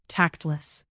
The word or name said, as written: tactless